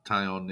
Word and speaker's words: Taillon: James Taillon. I think that's how you say his name. – Yeah, Taillon. Yeah, they traded for him.